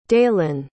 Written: daelan

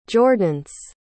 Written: jordence

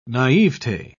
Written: naivete

